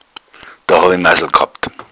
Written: massel

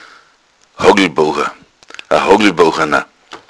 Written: hougelbouchana

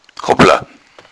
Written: hoppla

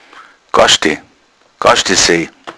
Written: gaschde

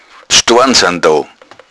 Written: staren

